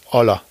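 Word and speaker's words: hola: Äh, es heißt nicht Holla, es heißt Olla. Das H spricht man nicht, Uli.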